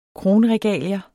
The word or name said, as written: kronregalier